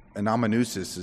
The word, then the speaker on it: amanuensis: We do know that Paul made use of someone, an amanuensis is how it's pronounced, but it's someone who's really writing for them, a scribe, as he dictates.